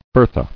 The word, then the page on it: Bertha: ber·tha